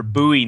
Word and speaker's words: Bowie: That, you know, they might pull their Bowie knives out. – You said it right. You said it right, and I'm proud.